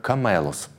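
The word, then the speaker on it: камелос (κάμηλος): По-гречески слово «верблюд» произносится как «камелос».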